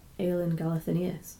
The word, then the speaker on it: Aelin Galathynius: How do you say that? Aelin Galathynius.